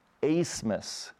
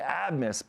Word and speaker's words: Abmas: is Max Asmus, okay? But his name is spelled A-B-M-A-S. Mm-hmm. And so for the longest time, I've been saying ABMIS because you just read it on paper and it's ABMIS.